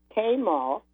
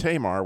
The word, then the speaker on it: Tamar: Which you pronounced Tamar, which, you know, it's up for discussion. I think you're probably right.